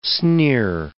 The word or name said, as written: sneer